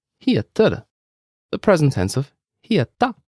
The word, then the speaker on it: heta: Heta belongs to a group of verbs with simple pronunciations. – All you need to know for now is that the stress is on the first syllable.